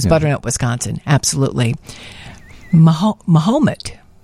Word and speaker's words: Mahomet: Mahomet. – Mahomet. – It's spelled Ma, then H-A-H, Ha, M-E-T, Mahomet. Boy, I bet that's confusing, because it sounds a little like Mohammed. Mahomet.